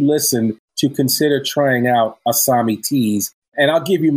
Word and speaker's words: ASAMI: And I should say it's spelled ASAMI, but it sounds like there's an H there between the with the S. So it's like ASAMI Teas.